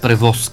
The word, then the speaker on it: превоз: Тя е без превоз, така ли? Да. – Няма превоз, както е правилното ударение.